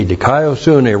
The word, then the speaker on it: dikaiosune: D-I-K-A-I-O-S-U-N, long E. Dikaiosune, righteousness.